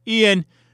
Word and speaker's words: Ian: And my first name is Ian, which actually stems from Hebrew many, many, many years ago as Eon, E O N, but it's spelled I A N.